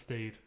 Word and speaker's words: staid: Yes, it's stayed. – Yeah. It was spelled like STAID for anyone that's wondering. It doesn't look like the word stayed.